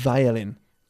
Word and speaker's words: violin: Violin teacher. – Violin. – Violin. – Eso es. Hay que vibrar, ¿qué? – El labio inferior. – ¿Dónde? – En los dientes superiores.